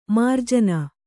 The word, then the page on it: ಮಾರ್ಜನಿ: mārjani